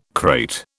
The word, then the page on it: crate: British English